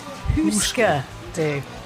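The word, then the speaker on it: Hüsker Dü: The proper pronunciation of the band's name is Husker Du, and was originally derived from the Scandinavian board game, which translates as Do You Remember? – Which is one of their songs.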